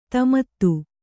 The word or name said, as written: ದಮಥ